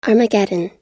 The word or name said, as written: armageddon